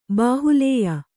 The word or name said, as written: ಬಾಹುಲ್ಯ